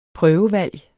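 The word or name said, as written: prøvevalg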